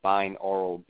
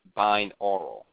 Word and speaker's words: binaural: It's called binaural beats, and that's spelled B-I-N, A as in apple, U as in umbrella, R-A-L, binaural, like oral you hearing, your audio.